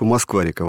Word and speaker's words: Москва-река: Отсюда родилось название группы «Москва-река» в одно слово с легким ударением на первую букву «А».